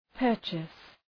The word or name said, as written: purchase